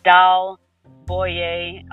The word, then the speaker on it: Dal Bouey: Well, I do have my personal development page is Dal Bouey, spiritual coach on YouTube.